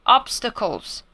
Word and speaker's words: obstacles: 第三个要注意的呢是 Obstacles，这个字大家也是很常用到，阻挠啊，阻碍啊，我碰到瓶颈啦，什么都可以用这个字，obstacles。那发音的部分呢，我们其实可以把它那个 t 后面那个 a，把它就念成单 t 这个音就好，obstacles，然后念个几次，拆开来把它念个几次之后，再把它装起来，obstacles。